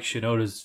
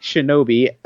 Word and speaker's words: Shinoda: for those that did listen last week, we did pronounce Mike Shinoda's from Linkin Park's name incorrectly. – Mike Shinobi. So. Which is a video game from, So thanks to Sean McKinney for pointing that one out.